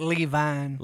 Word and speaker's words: Levine: Which you can get his book, All the Right Notes, on Amazon right now by Lance Levine. Levin? How do you say his name? – I like Levine. – Levine.